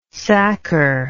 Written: sacker

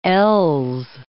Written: LS

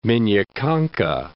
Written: Minya Konka

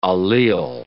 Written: allele

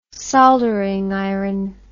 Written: soldering iron

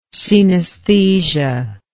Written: coenesthesia

